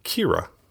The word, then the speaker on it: Qi'ra: And then we get Kira, Qi'ra, which would be pronounced Kira, which Kira was kind of the code name for Rey back when they were first making The Force Awakens.